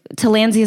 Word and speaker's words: Tillandsia: Tillandsia is the genus.